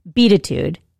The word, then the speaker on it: Beatitude: The title is pronounced Beatitude, which is connection of the words beat and attitude, which is probably why we feel like it should be called Beatitude.